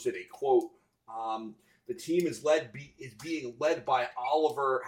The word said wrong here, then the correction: Olivier: Olivier. Wow. Not Oliver. Olivier Prou.